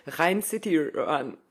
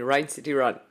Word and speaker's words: Rhein City Run: nee, ähm, heute war ich beim Ryan City... Ach, ich wusste, dass das passiert, ne? Rhein City Run. Ich kann das nicht aussprechen. Rhein City Run.